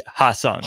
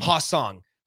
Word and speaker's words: Ha-Seong: I butcher his name. It's like H-A-S-E-O-N-G. – Yeah. Ha-Sung. – Ha-Sung.